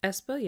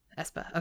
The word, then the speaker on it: aespa: aespa announced that they will be part of... I said that right, right? That's how they say their name? – aespa, yeah. – aespa, okay.